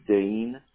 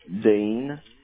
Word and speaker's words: Dean: Look, I go by my middle name, which is Dean. – Dean? – And I spell it Dean. – Okay. – Just like Gene, but with a D.